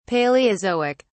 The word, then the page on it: palaeozoic: British English